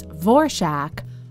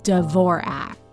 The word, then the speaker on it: Dvorak: She was not yet a teenager when she decided to change her last name to Dvorak, which she asked to be pronounced as Dvorak, although many people in Hollywood and elsewhere would pronounce it more or less phonetically as Dvorak.